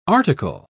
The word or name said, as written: article